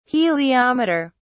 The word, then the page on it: heliometer: British English